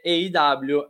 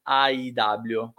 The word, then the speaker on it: AEW: Ma per favore, la pronuncia è EIW e non AIW.